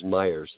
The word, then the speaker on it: Myers: Last name is Myers. It's spelled M-Y-E-R-S.